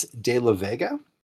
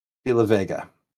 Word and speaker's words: De La Vega: His name is Usnavi De La Vega because it rhymes with bodega.